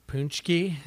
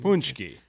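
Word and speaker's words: pączki: Yeah, did you get your punchki? – I that, did uh, several.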